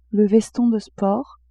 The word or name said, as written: veston de sport